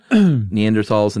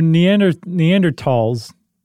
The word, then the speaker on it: Neanderthal: So Neanderthals, the correct pronunciation is tall, by the way.